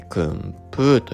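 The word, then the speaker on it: 薫風: 香る風って書いて訓風と読むんですが、もう一度呼ばせていただきます。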